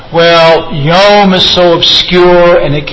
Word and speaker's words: yom: I just want to tell you about this Hebrew word, yom. Spell it in English, Y-O-M. It's not some Middle Eastern meditation. Yom. No. It's the Hebrew word for day, yom. Y-O-M.